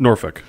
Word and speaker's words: Norfolk: So you got to see them play Norfolk. – I didn't know if that's how you pronounced it. – Norfolk, yes.